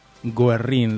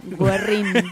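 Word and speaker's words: Güerrín: Mm, me gusta a las que a las que asisto como hombre homosexual que siempre terminamos en Guerrín. ¿Lo dije bien? – Lo dijiste bien, porque tiene diéresis. Recuerden, Guerrín.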